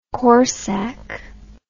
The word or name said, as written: corsak